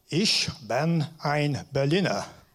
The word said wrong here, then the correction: Ich bin ein Berliner: Ich bin ein Berliner. – Oh, thank you. That's much better.